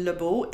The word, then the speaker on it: Lebeau: It's spelled LYN and last name Lebeau, LE, B as in Bob, EAU.